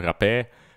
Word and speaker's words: rapé: Uh, det är alltså, no, jag tror svenskarna uttalar det som rapé, men det, det, det är, som man ska uttala det, det är ju ett litet apostrof ovanpå e.